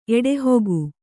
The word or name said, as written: ಎಡೆಹೊಗು